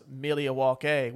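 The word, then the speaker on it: Milwaukee: Actually, it's pronounced Mealiwake, which is Algonquin for the good land.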